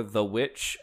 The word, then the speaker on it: The VVitch: The movie I did end up watching on a regular screen TV was The Vvitch, or The Witch, I think is how you were normally supposed to pronounce it, a Robert Eggers, flick.